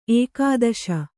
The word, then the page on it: ಏಕಾದಶ: ēkādaśa